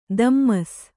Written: ದಮ್ಮಸ್

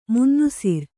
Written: ಮುನ್ನುಸಿರ್

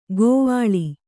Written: ಗೋವಾಳಿ

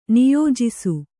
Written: ನಿಯೋಜಿಸು

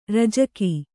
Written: ರಾಜಿಕೆ

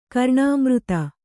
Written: ಕರ್ಣಾಮೃತ

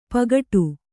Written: ಪೋಗೊಡು

